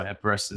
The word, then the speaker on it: Epirus: Epirus? – Epirus was the name of the bow of Theseus, infinite heroes in legend.